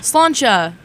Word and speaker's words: Sláinte: It means Sláinte. Health. It's okay. It's like a cheers when you're drinking. – Sláinte. – Yeah. – Did I say it right? – Yeah.